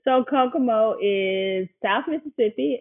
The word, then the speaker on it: Kokomo: You were from, and help me with the pronunciation, Kokomo, Mississippi, correct? – That is correct. Kokomo, Mississippi.